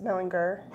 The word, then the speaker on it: Mellinger: How does he pronounce it? – A little weird. He always said Mellinger. – Is Mellinger. – Hard G. – Hard G. Ellinger Mellinger. Yeah.